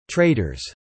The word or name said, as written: traders